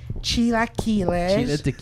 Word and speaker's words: chilaquiles: Mexican and chilaquiles. – Chilaquiles. – It's not chilaquiles. It's chilaquiles.